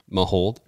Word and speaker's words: Mahowald: So Mahold is the pronunciation, but like when you say it fast, you really only say the two syllables, Mahold, but there really is three. – M-A-H-O-W-A-L-D.